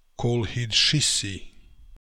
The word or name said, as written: Kolhidshissi